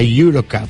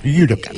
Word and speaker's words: Eurocup: de la Eurocup. Pronuncie conmigo, Eurocup. – Eurocup. – Correcto.